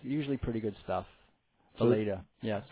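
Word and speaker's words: Weleda: It's called Walita. It's from Germany. It's been around 100 years, and it's not commercial. – Good. That's pronounced Valeda.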